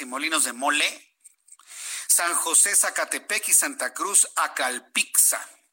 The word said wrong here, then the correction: Acalpixca: Acalpixca, perdóname si lo pronuncio mal, ¿eh?